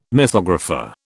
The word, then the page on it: mythographer: British English